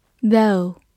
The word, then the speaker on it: though: though, t h o u g h, though, t h 发咬舌音 z，o u 发 o 这个双元音，末尾的 g h 不发音。